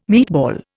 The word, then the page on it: meatball: Indian